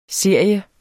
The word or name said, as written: serie